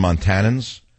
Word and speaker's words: Montanans: I guess you call them Montanans. Is that how you say it? Montanans? That's like tannins and tea or something.